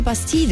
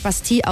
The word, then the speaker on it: Bastille: Er wird 40 Jahre alt und ich bin immer verwirrt bei der Band, weil die ja eigentlich Bastille ausgesprochen werden, aber aus England kommen und deswegen aus Amerika und deswegen Bastille zu sich selber sagen.